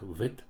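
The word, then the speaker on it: Бет (ב): Бет можно произносить как Бет и как Вет, Б, В.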